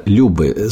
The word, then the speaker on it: любы: В пользу этой моей теории я хочу просто привести, я открыл этимологический словарь, и выяснил, что сама вот эта лингвистическая единица «любы» с ударением на последнем слоге впервые упоминается в древнерусских летописях XI века и означает это слово «страсть», «вожделение» и «привязанность».